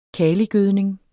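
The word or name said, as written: kaligødning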